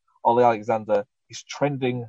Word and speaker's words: Alexander: Um, Ollie, okay, how how do you say his last name? Alexandra. – Alexander. – Alexander. Ollie Alexander.